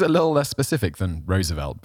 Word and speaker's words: Roosevelt: We did the Roosevelt one, which every time I say Roosevelt, I've got to think about the pronunciation. So Roosevelt, like he's a rose, is always how I imagine him.